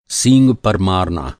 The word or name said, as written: सींग